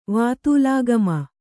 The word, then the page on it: ವಾತೂಲಾಗಮ: Kannada